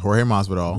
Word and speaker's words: Jorge Masvidal: Yes, I said that right. Leon Edwards versus Jorge Masvidal.